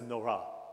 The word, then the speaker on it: Nora: Immediate things come into your mind when you hear Nora, but actually if you sound it on the second syllable, it's Nora, is actually what it is.